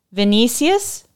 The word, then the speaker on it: Vinicius: And then as soon as I said that, Vinicius, did I say it right? – Yep.